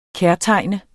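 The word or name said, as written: KÆRTEGNET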